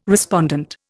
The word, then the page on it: respondent: Indian